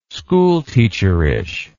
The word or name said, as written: schoolteacherish